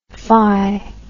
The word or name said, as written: fy